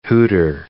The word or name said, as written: hooter